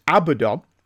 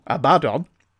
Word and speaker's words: Abaddon: Quick note, Abaddon, emphasis on the last syllable, Abaddon, still Abaddon, isn't a horrible pun you took it as.